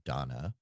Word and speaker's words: Dana: I found out a couple weeks ago that one of the franchises I enjoy, Wise, their Wise 8, the Lacrimosa of Donna. Yes, it's spelled Dana, but they pronounce, once I finally got to that character in the game, found out that they call her Donna, so which is messing with my brain.